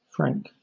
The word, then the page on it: franc: British English